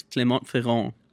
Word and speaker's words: Clermont-Ferrand: So it actually premiered at Clermont-Ferrand in International Film Festival at the beginning of the year, is in Clermont-Ferrand in France. – Okay. – It is... I'm pronouncing it incorrectly. – It's Clermont-Ferrand. – Yeah. There we go.